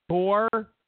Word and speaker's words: Gorsuch: You got Gorsh, or X, how do I say it? Gorshin? Is that it? Gorsh? How do you say it properly? Gorshin? Gorsh? He gets confirmed. Okay? Nuclear option, which means Ginsburg dies, Kennedy retires, Gorsuch. That's what it is. Gorsuch.